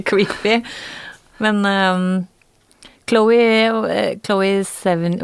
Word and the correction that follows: Chloé Sevigny: Jeg vet ikke hvordan man uttaler det. Sevigny. – Chloé Sevigny.